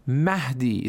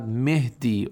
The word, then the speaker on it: Mahdi: For example, it is Mahdi. It is not Mehdi or Mehdi or something like that.